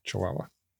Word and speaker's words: Chihuahua: Sorry, I should pronounce those correctly. It's Mojave, Baja, California, Sonora, and Chihuahua.